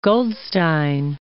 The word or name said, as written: Goldstein